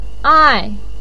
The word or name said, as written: ai4